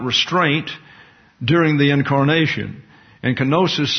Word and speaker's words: kenosis: The derivative of that verb is kenosis, pronounced in the English as kenosis and in your English Bibles as such.